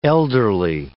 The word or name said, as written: elderly